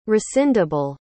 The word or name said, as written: rescindable